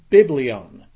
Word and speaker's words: biblion: Well, Bible comes from the Greek term biblion. You would spell that B-I-B-L-I-O-N.